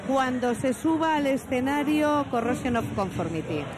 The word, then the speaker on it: Corrosion of Conformity: Corrosion of Conformity.